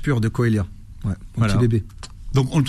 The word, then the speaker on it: Coelia: Et euh, ça s'écrit comment C O E L I A.